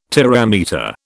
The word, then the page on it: terametre: British English